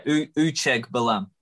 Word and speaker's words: Uchegbalam: I, I was pronouncing the G harder there. I think. – I think you were giving it a couple more extra syllables than what it required. It's uh, yeah, Uchegbalam.